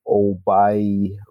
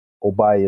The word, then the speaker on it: Oboi: My full name is Olatunji Obai Reed. And my handle on Twitter and Instagram is They Call Me Obai. Obai is spelled O-B-O-I.